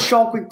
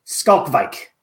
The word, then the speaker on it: Schalkwijk: I always thought it was Schalkwijk too, but it is Skalkwijk.